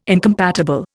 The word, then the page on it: incompatible: Indian